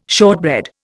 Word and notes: shortbread: Indian

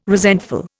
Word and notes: resentful: Indian

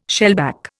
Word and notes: shellback: Indian

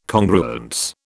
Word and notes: Congruence: British English

